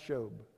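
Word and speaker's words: shuwb: It doesn't sound like shob, it's S-H-U-W-B, but it's pronounced shob.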